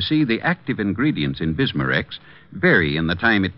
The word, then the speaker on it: Bismarex: That's right, ma'am. BISMA-REX. Bismarex.